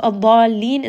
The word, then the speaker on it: الضالين: So if we look at the word al-dallin and then we look at the word al-ana, Let's look at the way that we emphasize the lam. So الضالين is going to be نبر. الضالين. Raise that voice and have emphasis on the lam.